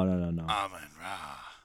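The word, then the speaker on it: Amon-Ra: Amon Ra. – Which you just got to say his name like Broncos country, let's ride.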